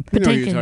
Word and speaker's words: Patinkin: Patinka? How do you pronounce his last name? – Patinkin.